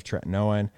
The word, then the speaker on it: tretinoin: What are your thoughts on retinol versus like a retinoid, like Retin-A, or like what's the generic, the tretinoin, am I saying that right? – Tretinoin.